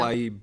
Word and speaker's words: Tlaib: Rashida Tlaib, is that how you say her name? I don't know. – Tlaib. – Tlaib, okay. Rashida Tlaib proposes a cash giveaway.